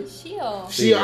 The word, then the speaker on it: Shio: Shio.